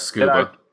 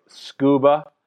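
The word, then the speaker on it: Scooba: You pronounced that correctly. Yes, Scuba. – Scuba.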